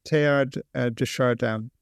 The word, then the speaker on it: Teilhard de Chardin: Am I saying his name correctly, Pierre Teilhard de Chardin? Is that how people say his name? – Yeah, Teilhard de Chardin.